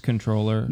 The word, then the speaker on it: comptroller: Now, when we say he's the controller, are we doing that weird government thing where the word is comptroller, but it's pronounced controller? – No.